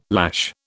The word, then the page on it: lash: British English